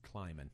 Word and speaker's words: Klieman: And then, help me pronounce this, the new guy at Kansas State, Chris Klieman.